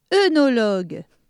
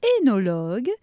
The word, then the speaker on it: œnologue: Alors, quel est le rapport entre tous les mots suivants Carrousel, abasourdir, gageur, dégingandé, œnologue, handball, tagliatelle.